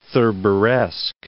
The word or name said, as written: Thurberesque